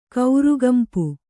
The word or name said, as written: ಕೌರುಗಂಪು